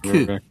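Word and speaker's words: ku (九): So look at the position of the letter not of the number 9 in written against Requiem, and realize that in Japanese the number 9 is pronounced ku.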